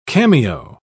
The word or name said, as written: cameo